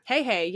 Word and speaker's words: Heihei: Hey, hey. Yeah. – Oh, I don't remember that. – It's spelled H-E-I-H-E-I, but still, hey, hey, is like the weird.